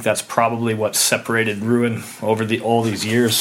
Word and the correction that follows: Rouen: Um, or Rouen. I got to put that French touch on it.